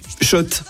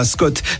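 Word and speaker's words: Schott: Oui, Shot et pas Scott. Ça fait des années qu'on prononce mal la marque.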